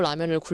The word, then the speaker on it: 라면: 너무 라면을 굴려서 발음하지 마시고요. 라면. 이렇게 똑부러지게 발음을 하셔야 됩니다.